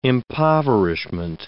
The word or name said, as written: impoverishment